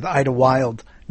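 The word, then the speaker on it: Idlewild: Well, that makes more sense because it has to rhyme with Ida Wild. Not Ida Wild, and a lot of people don't know that Ida Wild was the original name of the of JFK Airport.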